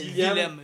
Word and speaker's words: Willem: Puis en allemand, le W se prononce V. Ah, ok. C'est William. – non, dans le fond, ça, c'est V. Dans le fond, ça fait Willem.